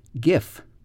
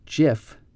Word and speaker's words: GIF: Well, here is a more recent acronym, GIF, sometimes pronounced GIF, G-I-F. That stands for Graphics Interchange Format. And that's a kind of a file that you might see on the internet. You might see a funny GIF or GIF. I think most people pronounce it GIF, but I have heard that the person who invented this kind of image or this kind of file prefers the pronunciation GIF. So, I don't know. Here's another one that I like. NIMBY. N-I-M-B-Y.